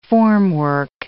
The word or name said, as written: formwork